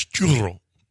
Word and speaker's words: churro: Like, it's churro. – But churro. It's two R. Yeah, a double R. But churro is the only way I know how to say it. It's a Spanish word.